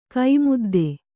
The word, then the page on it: ಕೈಮದ್ದು: kai maddu